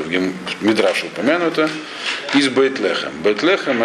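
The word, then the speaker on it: Бейтлехем (בית לחם): Просто Вифлеем в русском произношении – это Бейтлехем.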